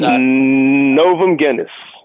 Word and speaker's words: Novum Genus: I believe Novum Genus, if I'm pronouncing that incorrectly. I apologize. But Novum Genus.